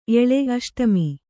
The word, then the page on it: ಎಳೆ: kannada